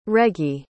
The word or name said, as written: regie